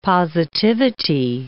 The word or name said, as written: positivity